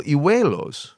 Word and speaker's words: hijuelo: have the little hijuelos. – Hijuelo. – Yeah, close enough. – Yeah. – It was pretty good pronunciation.